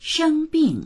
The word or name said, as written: sheng1bing4